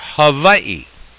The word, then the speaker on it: Hawaii: C-O-F-F-E-E. The answers are California, Florida, Texas, or Hawaii. Notice I pronounce that W with a V because that's the way that it's done.